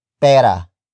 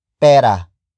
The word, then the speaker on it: pera: And that is pera. Pera. Pera. Pera is spelled as P-E-R-A.